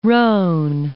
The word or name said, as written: Rhone